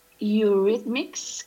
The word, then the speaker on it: Eurythmics: Vamos a empezar con la canción de Right By Your Side de Eurythmics, creo que se pronuncia correctamente. – Tal y como lo has dicho, veo que tú Eurythmics no le tienes, no está muy en tu radar, no.